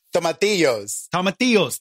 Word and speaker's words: tomatillos: Tomatillos.